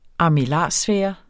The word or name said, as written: armillarsfære